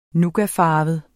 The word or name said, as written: nougatfarvet